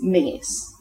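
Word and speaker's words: Menzies: According to the BBC pronunciation unit, the correct pronunciation is Mingus. And it rhymes with sing, but without the hard G. So the difference between finger and singer, it's more like the singer, Mingus, the soft G. Similarly, the Scottish surname spelled D-A-L-Z-I-E-L, pronounced D-L.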